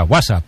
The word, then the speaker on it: WhatsApp: No, peor aún, con acento en la primera. WhatsApp, ¿no? Un WhatsApp.